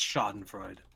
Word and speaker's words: Schadenfreude: Schadenfreude. – Is that how it's pronounced? – Yes, it is. It's pronounced Schadenfreude.